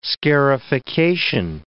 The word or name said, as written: scarification